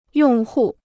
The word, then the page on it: 用户: yòng hù